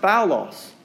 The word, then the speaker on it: phaulos: But this word bad, I think in Greek, is pronounced phaulos. Now, that doesn't mean foul, even though it sounds like the word foul.